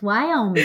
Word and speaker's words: Wyoming: So you have released, prior to your album Wyoming, did I say that right? I'm an Australian and can't pronounce states very well. – That was perfect. Wyoming.